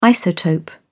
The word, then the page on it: isotope: British English